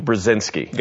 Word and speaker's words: Brzezinski: One of them is from a former national security advisor named, help me if I pronounce this wrong, Zinu Brzezinski.